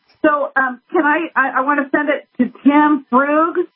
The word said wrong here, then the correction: Frugier: Yeah, Frugier. It's a French name. Frugier.